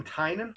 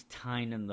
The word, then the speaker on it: Tynion: It was written by James Tynion IV art by Robson Rocha. – Oh, you mean Tynan? – James Tynan the Fourth.